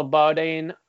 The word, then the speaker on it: Bodine: The third guy was Russell Bodine. That's how he told us to pronounce it.